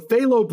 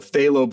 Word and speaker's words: phthalo: So phthalo blue is a good blue that I like, but how do you spell that? T-H-A-L-O? – It's P-T-H-A-L-O, phthalo blue.